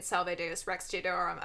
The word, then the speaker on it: Salve Deus Rex Deorum: Salve Deus Rex Deorum about how women are capable in a way that flawless pronunciation, though. Dang. I was like, my whole face just lit up.